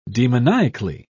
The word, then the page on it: demoniacally: British English